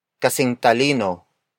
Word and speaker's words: kasing talino: Word for word, it's kasing talino ng panganay ang bunso. Kasing talino ng panganay ang bunso.